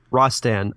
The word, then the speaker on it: Rastan: Is it Rastan? – Rastan? – Rastan. R-A-S-T-A-N? – Yeah.